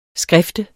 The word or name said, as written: SKRIFTES